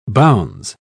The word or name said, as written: bounds